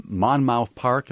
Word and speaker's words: Monmouth Park: This happened at Monmouth Park. Am I saying that right? – Yes, that's right. Yes.